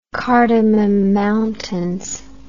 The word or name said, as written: cardamom mountains